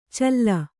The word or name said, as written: ಛಲಿ